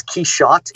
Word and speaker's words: Quichotte: It's Quichotte. It's Quichotte, and it's the French pronunciation of Quixote.